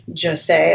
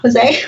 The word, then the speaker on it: José: Like, they grew up with José, as I learned is actually how you pronounce it. It's not José, but Panchito and Adela.